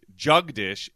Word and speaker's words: Jagdish: Interesting, because Jugdish is not a boy's name in India, but J-A-G-D-I-S-H, Jugdish is. And it's pronounced pretty much the same way from what I was able to glean online. It sounds like Jugdish, but it's spelled like Jagdish.